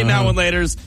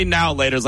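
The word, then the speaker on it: Now and Later: I say now and laters. You say now laters like it's all one word.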